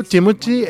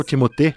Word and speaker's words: Timothée: O Timothée é o ator, né, principal, Timothée, na verdade a pronúncia, é o ator principal do musical Wonka, que está em cartaz.